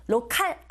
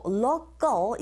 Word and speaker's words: local: 내용어에 힘을 준다라는 준비를 하시고 The words of the local, 로컬 아니고 local 이런 식으로 발음이 되죠.